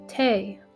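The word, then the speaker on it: Teatime: "It's pronounced Tame, sir," said Tea-Time, with just a hint of reproach. "Everyone gets it wrong, sir."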